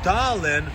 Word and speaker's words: Tallinn: Now, you know, Tallinn is spelled T-A-L-L-I-N-N. You would think it's actually pronounced Tallinn, but really it's pronounced Tallinn, like Thailand without the D, or like Tylenol without the O.